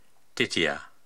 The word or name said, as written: Titiya